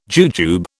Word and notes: jujube: British English

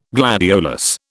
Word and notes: gladiolus: British English

